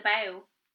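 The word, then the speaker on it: Badb: If it happened that any person of the same name, the Bav. – Bow. – The Bow. – I know, it looks like it's pronounced vibe, but it's Bow.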